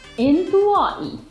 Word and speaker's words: انطوائي: Okay, so be careful about the pronunciation. انطوائي. Okay, if man he's talking, if woman she has to say انطوائية. One more time. انطوائية. There is طاء here, not تاء, not simple تاء.